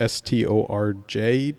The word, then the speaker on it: Storj: Around digi- digital, and, digital and, and, and we should say it's it's spelled funny, right? It's like S T O R J. – Yeah. S like like yeah like storage.